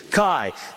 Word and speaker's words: chi: Sometimes you'll hear the word key come out of my mouth. That is not how you pronounce it. It is chi. It's like the letter X.